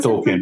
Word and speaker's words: Tolkien: É porque é Tolkien.